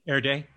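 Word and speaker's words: Erdély: Erdely.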